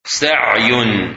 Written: Sai

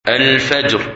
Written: Fajr

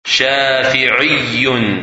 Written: Shafii

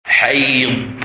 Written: Hayd

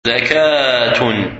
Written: Zakah

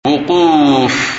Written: Wuquf

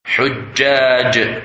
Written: Hujjaj

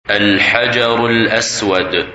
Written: Al-Hajar Al-Aswad

